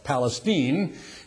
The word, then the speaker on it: Palestine: There's a town, a little town that they pronounce it over there incorrectly, call it Palestine.